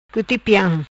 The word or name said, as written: Cuti Piam